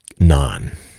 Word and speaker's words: Gnon: So they talk about the concept of non. It's spelled G-N-O-N with a silent G, which is an acronym for the God of nature or nature's law.